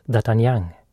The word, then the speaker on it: D'Artagnan: D'Artagnan. Namnet på bokens hjälte uttalas D'Artagnan.